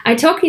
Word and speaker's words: italki: iTalki.